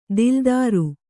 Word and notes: ದಿಲ್ದಾರು: dildāru

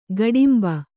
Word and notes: ಗೋಡುಂಬ: gōḍumba

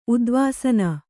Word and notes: ಉದ್ವಾಸನ: udvāsana

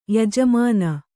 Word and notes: ಯಜಮಾನ: yajamāna

